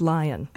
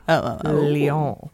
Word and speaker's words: Lyon: He was the chief named Meng Hongwei, who was reported missing after traveling to his native China from his home in Lyon. Lyon. – I like that. – Actually French. – Yeah. It's not pronounced lion. – Wait, L-E-O-N? – L-Y. – Lyon.